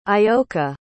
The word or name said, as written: ayoka